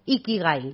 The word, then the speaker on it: Ikigai: Y lo arrancamos buscando nuestro Ikigai.